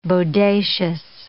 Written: bodacious

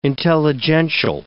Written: intelligential

